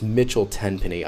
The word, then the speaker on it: Mitchell Tenpenny: Midtown Diaries by Michelle Tenpenny. I always say his name wrong. It's Mitchell Tenpenny.